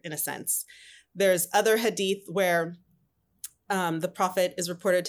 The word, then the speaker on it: Hadith: That's the Hadith, yes. – A Hadith.